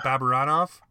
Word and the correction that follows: Barabanov: Bar- yeah, Barabanov. – Barabanov. Yeah.